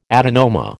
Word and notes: adenoma: American English

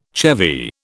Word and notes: chevy: British English

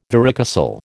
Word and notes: varicocele: American English

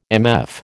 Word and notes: MF: American English

